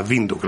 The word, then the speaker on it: Windhoek: Por cierto, aprovecho ahora para decirte que el nombre de la capital de Namibia se pronuncia Windhoek. No es Windhoek, ni Windhoek, ni nada por el estilo, es Windhoek. Otra opción es hacer escala en Sudáfrica, en Johannesburgo.